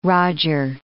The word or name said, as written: roger